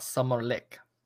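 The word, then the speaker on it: Sommarlek: So um, I will read here um, summer interlude, or how it is pronounced in Swedish? "Summerlek." summer lick. summer lick.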